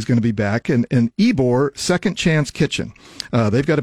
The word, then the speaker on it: Ybor: And we'll be joined by Bill Radke from Ybor. Am I saying that right, Johnny? Do you remember? – I believe, yeah, it's Ybor. – Ybor, thank you.